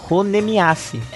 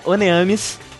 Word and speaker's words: Honneamise: Mas a pronúncia correta é Oneamis. E eu vou tentar manter o Oneamis.